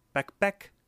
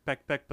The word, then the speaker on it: pekpek: So the Tagalog word for vagina is pekpek. It's P, that's spelled P-E-K, P-E-K. And when I was little, we said peck peck, but then we also said pep, like P-E-P, because mm-hmm. It sounded like peck peck.